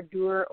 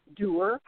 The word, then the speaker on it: Duir: A little bit further on down, due south, we have, looks like D-U-I-R, Dur or Dur pronunciation.